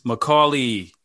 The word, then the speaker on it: Macaulay: Macaulay is, and am I pronouncing that correctly? – Yeah. – All right, cool. – You are, bro. I was surprised because people go Macaulay is a lot, people go with that a lot.